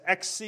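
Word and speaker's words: exe (έξι): The Greek for 6 is exe.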